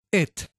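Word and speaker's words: et (את): Bueno, en hebreo moderno, la palabra Alef-Taf, esta pequeña palabra de 12 letras, la primera y la última letra, se pronuncia et.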